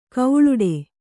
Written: ಘೀಳಿಡು

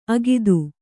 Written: ಅಗಿದು

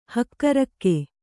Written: ಹಕ್ಕಿರೋಗ

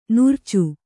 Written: ನಾರಾಚಿ

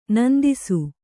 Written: ನೊಂದಿಸು

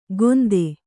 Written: ಗೊಂದೆ

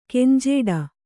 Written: ಗುಂಜಾಡು

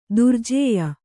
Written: ದುರ್ಜೇಯ

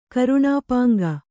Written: ಕರುಣಾಪಾಂಗ

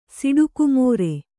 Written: ಸಿಡುಕುಮೋರೆ

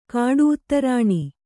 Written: ಕಾಡುಉತ್ತರಾಣಿ

